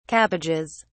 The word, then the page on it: cabbages: British English